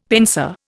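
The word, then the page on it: pensée: Indian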